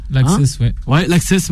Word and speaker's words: L'Access: L'Access, voilà, on le prononce comme ça.